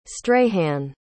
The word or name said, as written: strahan